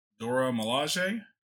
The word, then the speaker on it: Dora Milaje: It's like you get to play between four characters, Black Panther, Captain America, someone from the Dora Milaje.